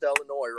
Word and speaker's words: Illinois: Okay, first off, I'm going to give you a round of applause, because you actually pronounced Illinois right this time.